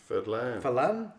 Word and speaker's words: Fadlan: Today's film falls somewhere in the middle, taking the stories of Beowulf and the real-life Baghdadi poet Ahmed ibn Fadlan, I can never say his name. – Fadlan, and merging them into an old-timey adventure story of unnamed monsters.